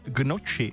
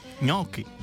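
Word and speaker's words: gnocchi: A taky Marťa, ahojda, tak mě tahá za uši, když někdo řekne, že by si dal gnoči. Správně je ňoky.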